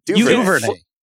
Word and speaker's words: Duvernay: Duvernay. You almost say it like you're trying to have a French accent when you say it.